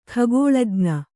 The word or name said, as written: ಖಗೋಳಜ್ಞ